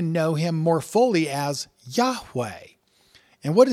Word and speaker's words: Yahweh: Several early Greek writers of the Christian church testify that the name was pronounced Yahweh.